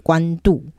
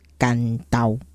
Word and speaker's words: 关渡: 这个地名，我们应该是要读作关都、其实这个所在叫它的古宅，的读法就是甘刀。 – 对，那因为云长就是关公本人啊，关云长，所以关先生渡海，过海，所以是关渡。那国语汉字是写关渡没有错，但是当地的古早名字的发音其实是甘刀。